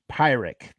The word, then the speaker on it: Pyrrhic: Um, Pyrrhic, a pirate. Sorry, so it's Pyrrhic. That's how you pronounce it.